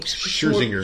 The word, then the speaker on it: Scherzinger: Scherzinger.